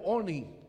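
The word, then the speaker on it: oni (עני): Esta palabra, mis amados, aflicción, viene de una raíz, de una raíz, perdón, que se pronuncia en hebreo oni y que además tiene otros significados como depresión, angustia, miseria.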